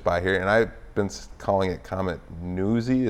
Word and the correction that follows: NEOWISE: Am I saying it right? – Neowise. – Neowise, okay.